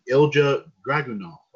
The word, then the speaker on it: Ilja Dragunov: get by a very unique Russian superstar in Ilja Dragunov.